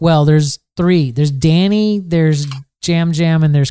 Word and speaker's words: Yam Yam: It's spelled Y-A-M. Y-A-M. – Yeah, come on. – It's called... – Please. – Spelled Y-A-M, Y-A-M, but it's pronounced Jam Jam.